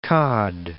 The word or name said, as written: Cod, Cape